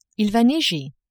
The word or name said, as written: il va neiger